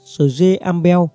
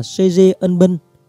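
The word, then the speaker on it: Srae Ambel: Nhiệm vụ của sư đoàn tôi bảo vệ một tuyến dài trên 70 km từ xã Sje Ambel, người Việt mình đọc là Sje Ân Bân, thuộc huyện Sje Ambel, tỉnh Cô Công, đến toàn biên giới tỉnh Cô Công.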